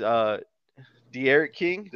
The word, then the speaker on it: De'Eriq King: Probably won't get drafted, but has a chance, is De'Eriq King.